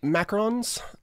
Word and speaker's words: macaron: She sent us some macarons, the little French. – Is that how you pronounce it? – Macaron. – The French president? – Not macaroons, which are like the coconut cookie things or whatever.